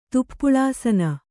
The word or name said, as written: ತುಪ್ಪುಳಾಸನ